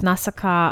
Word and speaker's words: Nausicaa: My blind animation is Nausicaa of the Valley of the Wind.